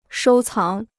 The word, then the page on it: 收藏: shōu cáng